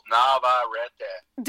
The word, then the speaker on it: Navarrette: They ask me how do you pronounce your name, and I say four syllables: Navarrette.